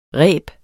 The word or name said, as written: ræb